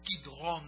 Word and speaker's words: Kidron: I love that word, Kidron. That's how you say it in the Hebrew language.